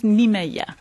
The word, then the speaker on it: Niemeyer: En fait, ils ont plusieurs activités entourant l'architecte brésilien Oscar Niemeyer. Il paraîtrait que lui-même prononce plus Niemeyer.